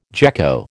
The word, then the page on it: gecko: American English